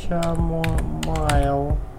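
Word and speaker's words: chamomile: Как оно произносится? Чамомайл. – Чамомайл. – Так произносится. Раз другого слова.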